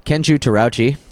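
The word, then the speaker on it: Terauchi: And then the captain, his name is Captain Kenju Tarauchi. Believe I'm saying that right. It was pronounced a couple different ways, but that's what they pronounced it on the documentaries.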